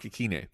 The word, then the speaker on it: Ikhine: So when you're looking, like we had a waiver show yesterday, we talked about Westbrook Akeen. How do you pronounce it? – Westbrook Ikhine. – Ikhine, not Akeen.